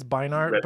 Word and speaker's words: Beinart: Is that how you pronounce it? – I think it's Beinart, but yeah, whatever.